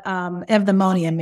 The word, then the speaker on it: eudaimonia: I'm like, how do you say this? Because it doesn't, it's not spelled how you say it. – Right. – So spell it for us real quick and tell them what eudaimonia means. – Okay. Eudaimonia is E-U-D-A-I-M-O-N-I-A.